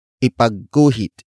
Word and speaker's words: ipagguhit: Ipagguhit mo ako ng bulaklak. Ipagguhit mo ako ng bulaklak.